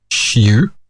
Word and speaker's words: Xiu: The Xu Zhen is actually X-I-U-Z-E-N. X is pronounced sh in pinyin, so it's Xiu. And Z-E-N, Zen, Xu Zhen.